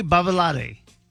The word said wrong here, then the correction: Babalade: Hold on. I want you to take a deep breath and repronounce those names. – Woody Marmaduke or Tree Babalade? – Spelling. – B-A-B-A-L-A-D-E. – B-A-B-A-L-A-D-E.